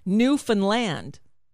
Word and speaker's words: Newfoundland: I've heard it many times from people who live there. Newfoundland. Newfoundland. Right, that's what I said, Newfoundland.